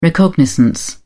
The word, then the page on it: recognizance: British English